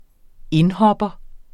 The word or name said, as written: indhopper